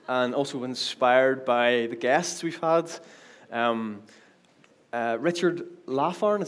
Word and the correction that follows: Larn: is that how you... – Larn? – I butchered that last week. Yeah. Larn. Richard Larn.